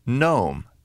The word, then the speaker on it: gnome: W-R-I-T-E. The letter G is silent in the word gnome, G-N-O-M-E.